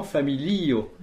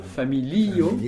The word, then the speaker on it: familio: Quand on dit yes, on est, pour dire oui, on écrit J-E-S. Et, mais je pense qu'en donc en espéranto, il faut bien dire familio, puisque la dernière syllabe, c'est le O tout seul, et l'avant-dernière syllabe, c'est le I.